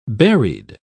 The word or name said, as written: buried